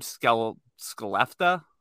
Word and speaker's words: Skellefteå: I don't know how to say the name, Skellefteå. – Yeah, that's exactly how you pronounce it. with the like lack of confidence, that's how you say it.